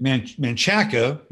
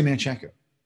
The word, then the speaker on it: Manchaca: Yeah, Texas has weird pronunciations, like Manchaca, which is M-A-N-C-H-A-C-H-A, Manchaca, M-A-N-C-H-A-C-A, Manchaca. You don't say Manchaca, you say Manchac.